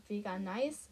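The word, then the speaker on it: Veganice: Wie spricht man das aus? Vegan Nice. Vegan Nice. Ja, wegen dem Nice.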